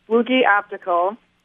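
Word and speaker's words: Fluegge: It's Flugi Optical. It's spelled F as in Frank, L-U-E-G-G-E, Flugi Optical.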